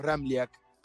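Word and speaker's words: Ramljak: Es un poco complicado porque tiene tres consonantes juntas y la J se pronuncia como una I. Bien pronunciado el apellido es Ramliak.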